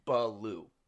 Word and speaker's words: Ballew: Baloo. Like the bear in the Jungle Book, if you remember that character. – I know. – Phonetically, it would be spelled B-U-H-B-A-L-O-O. Baloo. – Oh, yeah. Yeah. – But it's spelled B-A-L-L-E-W.